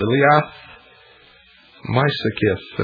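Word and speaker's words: Liath Meisicith: and this brooch was called the Liath Maisakith, and I believe that I pronounced that sort of right. It's spelled L-I-A-T-H, the second word, M-E-I-S, I-C-I-T-H.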